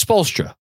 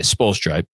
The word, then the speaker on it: Spoelstra: Andrew's correcting my pronunciation. It's Spoelstra. That's how I say it. Spoelstra. I mispronounced before.